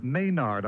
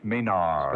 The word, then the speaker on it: Maynard: Dave Maynard. I remember when you used to make people pronounce it properly, David. And I always learned, never Maynard. – No. – Like nerd, Maynard.